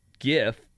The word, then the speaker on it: GIF: Instead of just GIF, the jars will read JIF, soft G pronunciation on one side, and GIF, GIF, hard G pronunciation on the other.